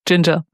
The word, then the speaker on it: Jinja: Jinjar schreibt man nicht wie Ingwer, sondern J-I-N-J-A. – Ah, natürlich, ja genau, das könnte... genau. – Fällt mir gerade auf, dass man es genauso ausspricht wie Jinjar.